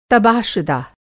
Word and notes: तबाहशुदा: Hindi

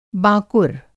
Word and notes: बाँकुर: Hindi

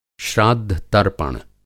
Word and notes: श्राद्धतर्पण: श्राद्ध-तर्पण

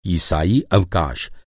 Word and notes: अवकाश: Hindi

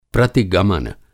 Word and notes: प्रतिगमन: Hindi

